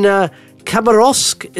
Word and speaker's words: Khabarovsk: And especially, I don't know what time it is where you are, but you're, excuse the pronunciation, but you're in Khabarovsk, is that right? – That's correct.